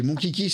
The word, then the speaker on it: Monchichi: Alors, c'est pas Kiki, Kai. C'est mon Kiki, ça s'écrit M-O-N-C-H-I-C-H-I.